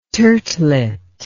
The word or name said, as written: turtlet